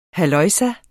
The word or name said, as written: halløjsa